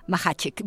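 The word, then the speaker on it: Macháček: Él es Jan Machacek, se escribe Machacek... pero se pronuncia Machacek,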